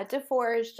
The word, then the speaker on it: DeForge: Yeah, DeForge. Just pretend there's no S's and you're good.